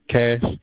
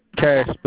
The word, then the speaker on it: Cache: Pseudonym, last name, C-A-C-H-E, Cash. Some people pronounce it Cache. – C-A-C-H-E, like cachet.